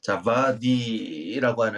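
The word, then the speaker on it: Djawadi: 찾아보니까 자바디 W를 그 V 발음으로 읽더라고요. 어, 자바디라고 하는 이분이 영화 음악을 맡았습니다.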